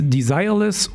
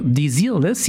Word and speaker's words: Desireless: Hier ist Desireless oder Desireless, je nachdem, wie man es aussprechen möchte, mit Voyage, Voyage.